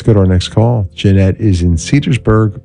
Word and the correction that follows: Cedarburg: Wisconsin. Cedarburg. Did I say that right? Yeah, Cedarburg. It's near Milwaukee, if I'm correct.